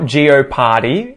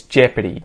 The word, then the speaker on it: Jeopardy: It's not Geoparty, it's Jeopardy.